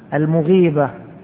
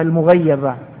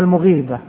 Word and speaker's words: المغيبة: المغيبة هكذا نطقها، بعض الناس ينطق المغيبة، هذا ما جاءت أبدا بها الرواية، ما جاءت الرواية بها لا في لغة العرب ولا في الحديث، وإنما المغيبة، المغيبة التي غاب عنها زوجها.